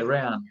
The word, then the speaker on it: Reyon: Okay, Reyon.